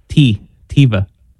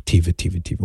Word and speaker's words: Taoimha: I mean, if you were to look at how it's spelled, T-A-O-I-M-H-A, you would not think, unless you were from Ireland, you wouldn't think Tiva, but that's how I guess it's pronounced. T-A-O-I-M-H-A spells Tiva.